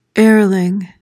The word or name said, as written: airling